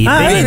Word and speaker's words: beninesi: Beninesi. L'hai detto bene alla prima, vedi? – Beninesi.